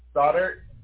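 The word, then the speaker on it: Stoddart: Stoddard. – Goddard. Spell it one more. – O-D. – S-C-O. O-D. – D-D-A-R-T.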